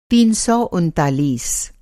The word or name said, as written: तीन सौ उनतालीस